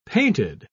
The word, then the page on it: painted: British English